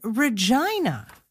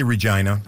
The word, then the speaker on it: Regina: It is Regina. – That's a fair Regina observation. – Threw Ryan. It threw all of us. – You could say Regina. You could. – You could, but you'd be mispronouncing it.